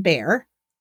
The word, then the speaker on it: Bair: She was found on Sunday morning, August 28th, at 7:30 by William Bear," not spelled the same, B-A-I-R, "but she was sound and well.